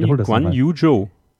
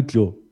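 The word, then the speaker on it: Zhou Guanyu: Guan, Guan Yu Zhou. Guan Yu Zhou. Zhou. Okay. Zhou. Man kann ihn, glaube ich, auch Zhu aussprechen, aber ich nenne ihn Joe.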